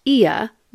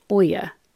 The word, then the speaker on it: Oia: Spelled O-I-A, but pronounced Ia, the O is silent and the I is a long E. Definitely not Oya, and knowing this will score you lots of points with the locals.